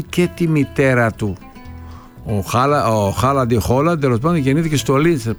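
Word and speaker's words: Haaland: Λοιπόν, πάμε τώρα να δούμε τα δικά μας, αφού πούμε δύο λόγια για τον Χάλαντ, ο οποίος έγινε γνωστός σήμερα γιατί στη φανέλα του, όπως ο Σταυρούλιας, λοιπόν, έτσι και ο Χάλαντ, ο οποίος προφέρεται Χόλαντ, να το ξέρετε αυτό, στη φανέλα του δεν γράφει μόνο Χάλαντ, γράφει Μπρουτ Χάλαντ.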